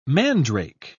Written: mandrake

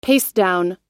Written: pastedown

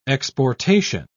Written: exportation

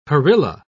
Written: perilla

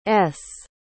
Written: s

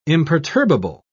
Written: imperturbable

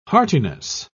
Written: heartiness

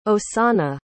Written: osanna

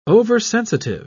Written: oversensitive

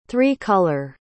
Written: three-color

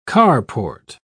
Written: carport

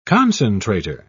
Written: concentrator

